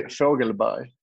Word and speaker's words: Fogelberg: It's actually Fogelberg.